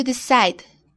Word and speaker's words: decide: D-E-C-I-D-E. E. – Correcto. Hay una E muda al fin de la palabra. No la pronuncias. Así que el sonido al fin de la palabra es D.